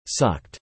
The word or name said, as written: sucked